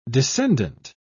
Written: descendant

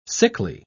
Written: sickly

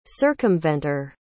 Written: circumventer

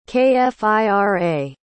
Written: kfira

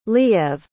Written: liev

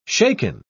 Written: shaken

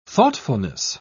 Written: thoughtfulness

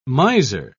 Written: miser

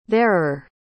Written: there're

